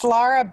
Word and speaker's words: Lara: Yeah, it's Lara Beitz, and I like.